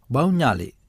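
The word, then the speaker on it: Bau Nyale: se compone de dos sílabas, a saber Bau, Bau, que significa atrapar, y Ñale, Ñale, que significa gusano de mar. Bauñale, Bauñale, significa atrapar gusanos de mar.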